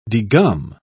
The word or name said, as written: degum